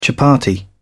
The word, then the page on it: chapatti: British English